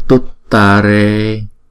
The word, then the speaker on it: tuttare: Còn chữ này là tuttare, vì chữ U là chữ U ngắn, chữ U không có dấu gạch ngang trên đầu, cho nên chúng ta đọc là tuttare, tuttare, ture, ture.